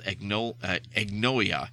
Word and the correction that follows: Agonia: I was about to say Agnolia, but that's not it. Agonia.